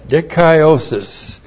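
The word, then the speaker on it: Dikaiosis (δικαίωσις): Dikaiosis looks like this. D-I-K-A-I long O-S-I-S. Dikaiosis.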